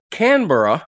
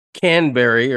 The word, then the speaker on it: Canberra: I was sort of surprised that Canberra, which I would have pronounced Canberry or something if I hadn't looked up how to pronounce it, was the capital.